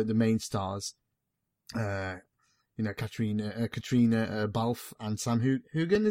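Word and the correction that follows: Heughan: is it? – You wouldn't pronounce it like that. – How would you pronounce it? – It would just be Sam Hewen. – Oh, Hewen, right.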